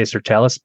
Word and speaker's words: sirtalis: but is it sirtalis or sirtalis? – I always say sirtalis, but all right, we'll go sirtalis. – I don't really know either. – but when you're looking sirtalis,